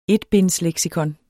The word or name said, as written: etbindsleksikon